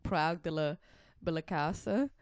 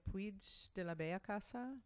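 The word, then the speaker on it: Puig de la Bellacasa: First I want to say about, I would call her Maria Puig de la Balacasa, but that's how I say her name.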